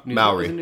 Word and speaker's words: Maori: It's like Maury, it's like, what's it, the Maury, like, tattoos? Is that, am I saying that right? – Oh, like. – Yeah, in, like, the Polynesia, or, like, the. – Yeah, Maori. – Is it Maori, thank you.